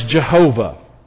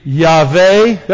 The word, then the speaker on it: Yahweh: It has been pronounced widely as Jehovah. Most Bible scholars will say that is probably not a correct translation of that. It could be Yahweh.